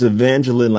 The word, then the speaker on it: Evangeline: It's Evangeline, like Jacqueline.